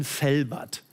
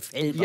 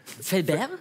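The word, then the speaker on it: Fellbach: Es gibt aber so viele Leute sprechen das falsch aus, weil wir auf der anderen Rheinseite sind, die denken, das ist schon so weit an Frankreich, dass man das. – Fellbert? – Ja. Ja. So viele Leute wollen das immer so aussprechen, deswegen, ja, du hast das genau richtig ausgesprochen.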